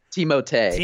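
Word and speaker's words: Timothé: Is it like Timothy? – Timothy, I think. Timotei. – Timotei.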